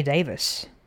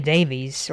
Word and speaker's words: Davies: Okay, well, I have got a single released on December 4th, 1984, and it was written by a guy named Ray Davis, or Ray Davies, or mm-hmm. Davies. There's different pronunciations I've heard, but you know where I'm going with this, don't you? – I do.